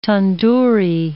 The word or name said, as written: tandoori